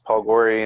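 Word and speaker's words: Paul Gori: Facebook, they can just look up my name, just Paul Gorey, and you'll see my...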